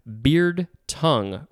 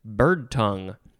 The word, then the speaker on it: beardtongue: Next, we have beard tongue. Or no, is it? I think it's just bird tongue, but it's spelled like beard.